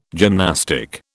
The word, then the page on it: gymnastic: British English